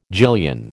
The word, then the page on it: gillion: American English